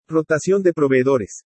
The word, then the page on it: ROTACION DE PROVEEDORES: spanish